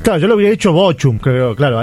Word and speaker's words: Bochum: Bochum.